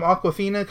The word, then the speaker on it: Awkwafina: Comedy Central has a series from Awkwafina called Awkwafina is Nora from Queens.